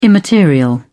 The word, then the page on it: immaterial: British English